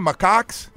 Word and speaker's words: Macaques: Macaques.